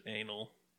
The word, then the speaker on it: Enel: Although in Italy they pronounce it anal, which I will never stop laughing at.